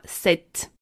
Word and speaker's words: cet: En revanche, on ajoutera un « t » à « ce » et on le prononcera « cet ». C-E-T.